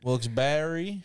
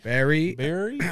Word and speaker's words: Wilkes-Barre: You better pronounce that E. That's what I learned. – Wilkes-Barre. – Barry.